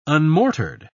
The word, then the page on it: unmortared: British English